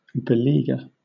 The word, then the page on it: beleaguer: British English